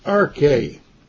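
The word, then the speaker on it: arche (ἀρχή): And it is pronounced in the Greek arche.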